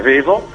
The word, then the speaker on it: Vevo: Sì, proprio su Laika c'è un videoclip che è uscito a metà settembre e che trovate sul nostro canale Vevo, per cui o Vivo, insomma, dipende se che pronuncia Vevo, Vivo.